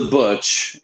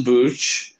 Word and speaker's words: booch: Well, um, first, you don't want to call yourself the Butch. Uh, if you're going to do that, definitely pronounce it Booch.